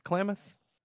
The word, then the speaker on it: Klamath: Talking about United Kingdom, 30th anniversary, San Francisco's 25th anniversary, and Klamath. Am I saying that right? Klamath? – Yeah.